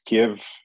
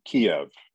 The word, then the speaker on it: Kyiv: okay, so for everybody watching this, it's Ukraine and it's Kyiv, not Kiev. Is that correct as well? – Yes, absolutely right. – And that is the Ukraine pronunciation instead of the Russian pronunciation.